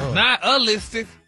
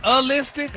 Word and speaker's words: nihilistic: Stop putting the ooh in there. – Not a-listic? – There you go.